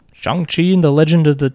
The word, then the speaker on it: Shang-Chi: Unlike you, I did watch Shang-Chi.